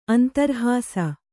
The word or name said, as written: ಅಂತರ್ಹಾಸ